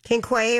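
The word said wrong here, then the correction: Ke Huy Quan: or how do we say his name? His name is Ki-Hui Kwan.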